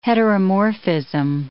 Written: heteromorphism

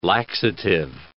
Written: laxative

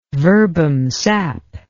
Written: verbum sap

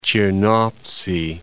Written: Chernovtsy

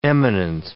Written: eminent

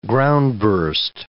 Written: groundburst

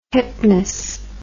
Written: hypnus

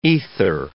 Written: ether

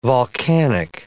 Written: volcanic

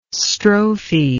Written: strophe

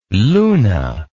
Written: luna

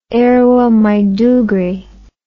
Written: Yerwa Maiduguri

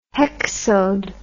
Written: hexode

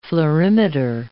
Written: fluorimeter

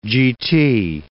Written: GT

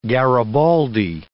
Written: garibaldi

